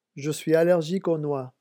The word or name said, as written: je suis allergique